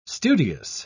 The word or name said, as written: studious